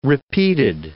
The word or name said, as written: repeated